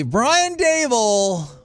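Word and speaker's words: Daboll: Dable? – Well, since you said it correctly, Brian Dable also in the mix.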